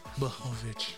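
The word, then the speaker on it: Błachowicz: Blahovic. – There you go. Ugh. K silent. – Oh, gross. There is no K. – See, so if you just remember K silent, you'll pronounce it correctly.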